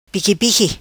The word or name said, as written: Piqui Piqui